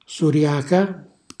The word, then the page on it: suriaca: Calabrese